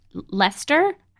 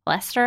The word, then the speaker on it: Leicester: I think that's how you pronounce it. British towns like have like a million letters in them, and you don't pronounce like half of them. – Yeah. – So, Leicester, I think, but it looks way longer than that.